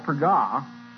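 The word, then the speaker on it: pagah (פָּגַע): It's spoken, rather pronounced, as I know it, pagah.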